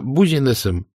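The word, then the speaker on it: business: Я заметил, сеньор Манолетто называет дело бузинесом. Это он так шутит? – — Нет, — ответил Штирлиц. — Многие испанцы именно так произносят это американское слово.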